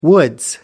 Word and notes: woods: British English

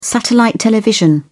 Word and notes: satellite television: British English

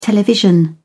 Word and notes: television: British English

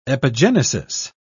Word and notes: epigenesis: British English

